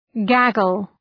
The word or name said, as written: gaggle